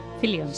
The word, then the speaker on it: Fillion: Nathan Fillion, ¿lo digo, ¿lo digo bien? – Fillion, sí.